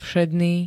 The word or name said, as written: všedný